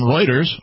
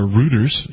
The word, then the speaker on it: Reuters: This is actually from Reuters, or Reuters, if you want to mispronounce it. R-E-U-T-E-R-S.